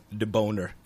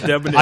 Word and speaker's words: Debonair: But I also just want to say this word and say it like this, deboner. – I got it. – Debonair. – I got deboner.